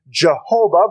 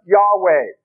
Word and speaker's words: Yahweh: Most scholars today think it's not proper to pronounce the name Jehovah, but Yahweh.